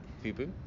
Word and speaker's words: FUBU: FUBU.